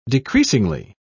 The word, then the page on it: decreasingly: British English